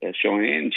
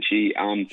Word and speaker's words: Cheyenne: Obviously, she's Tiger Woods' niece, Cheyenne. – Cheyenne.